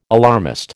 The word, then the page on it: alarmist: American English